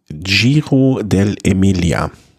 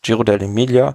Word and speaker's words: Giro dell'Emilia: Fangen wir doch mal einfach an mit dem Giro dell'Emilia.